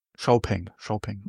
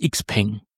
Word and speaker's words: XPeng: Xiaopeng, okay. So hat es mir jedenfalls der Kollege von Xiaopeng erklärt. Gut. Hört sich auch schöner an als Xpeng.